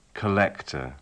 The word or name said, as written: collector